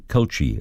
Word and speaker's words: culchie: 13 to 2, and to the word this morning, Kulchi. I'll spell it. C-U-L-C-H-I-E, Kulchi. It's a noun, and it means someone from rural Ireland or a small country town.